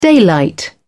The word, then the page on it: daylight: British English